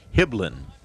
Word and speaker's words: Hibbeln: Spells his last name H-I-B-B-E-L-N. Pronounced Hiblin. And he is the new pitcher.